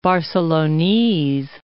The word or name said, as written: Barcelonese